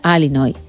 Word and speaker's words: Illinois: Bien, sigue, no sé si lo habéis escuchado, yo sí lo he escuchado, dice que pronuncia Alinoy.